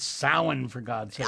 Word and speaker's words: Samhain: Samhain. – Yeah, I know, but they don't say it like that. – They don't. – No, they don't. And you can yell at them. I'm not going to. – I will. – But the I just did. – You guys, you're saying it wrong.